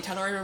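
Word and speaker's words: Tal'Dorei: Yes, so Critical Role streaming, Critical Role, the new, and I'm going to say this wrong, is it Tal Dorei? – Yes, the Tal Dorei Reborn, which should release, I believe it was at the 18th.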